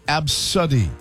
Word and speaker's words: ABCDE: Top of the list, the name, it's spelled A-B-C-D-E, pronounced Absuddy. Absuddy.